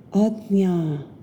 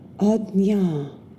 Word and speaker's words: Ajna: The third eye chakra, or the brow chakra, is pronounced Ajna, Ajna.